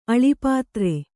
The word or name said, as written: ಅಳಿಪಾತ್ರೆ